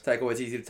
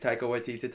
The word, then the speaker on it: Taika Waititi: Taika Waititi, Taika Waititi, Taika Waititi.